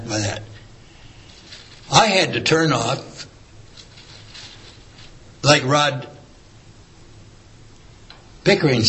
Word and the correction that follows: Pickler: said, did I say that right? – Pickler. – I'm sorry, Rod.